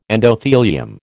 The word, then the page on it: endothelium: American English